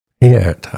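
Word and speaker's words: Pärt: Yes, Estonian composer Aavo Peert, I think is how he pronounces his last name. I was reading up on him a bit over the weekend and realized that P-A-R-T is not pronounced part.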